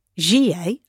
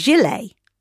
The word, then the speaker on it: gilet: And I said the word Gie, as we say it. – Yeah. – And my co-host, Marianne, went, what the hell are you doing? And she asked ChatGPT, which confirmed that the pronunciation is Gilet.